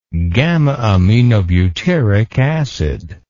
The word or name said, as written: gamma aminobutyric acid